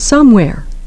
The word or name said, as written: somewhere